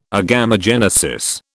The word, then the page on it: agamogenesis: British English